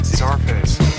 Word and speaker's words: Czarface: It's Sarface. – Inspector Deck, Esoteric, and 7L. – Sarface. – Sarface. It's Z-A-R. – Was it Zarface, not Scarface? – I can't ever seem to say it right.